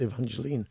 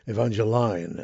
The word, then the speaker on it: Evangeline: I should have said not Evangeline, Evangeline, as it's pronounced in Louisiana.